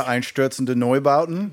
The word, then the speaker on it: Einstürzende Neubauten: yeah, Einstürzende Neubauten.